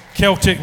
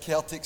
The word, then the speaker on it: Celtic: And it said Celtic Springs on it, but I'm from Glasgow. We pronounce the C differently through there with an S.